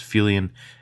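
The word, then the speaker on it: Feuillien: That's Fulian as in fuel, but not yet. Listen, it's a little different.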